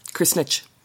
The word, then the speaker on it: Krsnich: And then K-R-S-N-I-C-H. Kersnitch.